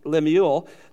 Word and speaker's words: Lemuel: The words of King Lemuel is the Hebrew pronunciation of it. Lemuel, the oracles which his mom, his mother, taught him.